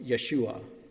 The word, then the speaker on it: Yeshua: I mean the names are the same names in the Hebrew language, pronounced Yeshua, meaning Yahweh saves.